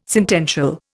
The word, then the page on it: sentential: Indian